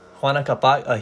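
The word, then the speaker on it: Huayna Capac: So, what do you think about Hyena Capac? Correct me if I pronounced that wrong, but go ahead. – Juana Capac, he was a really interesting guy.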